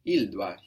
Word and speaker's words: doit: Il doit. Here we have a silent T. We have DOIT.